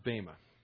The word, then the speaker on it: Bema: It's pronounced Bema. But if you like Bema, you keep calling it that.